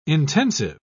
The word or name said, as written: intensive